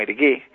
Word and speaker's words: Hergé: y en 1924 ya firma con el seudónimo Hergé, que es la pronunciación en francés de las iniciales RG de su nombre, ¿no?